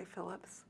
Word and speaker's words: Phillips: Beverly Phillips, Phillips.